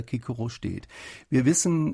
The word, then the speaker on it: Cicero: Zu Ciceros eigener Zeit ist mit Sicherheit Cicero gesagt worden, denn es gibt griechische Quellen aus der Zeit und die überschreiben das mit K, mit Kappa im Griechischen. Sie hätten auch die Möglichkeit gehabt, das mit Säter zu umschreiben. Und insofern ist es klar, dass es damals Kikoro ausgesprochen wird.